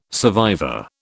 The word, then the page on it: survivor: British English